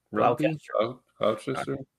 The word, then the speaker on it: Gloucester: Gloucester? – Gloucester.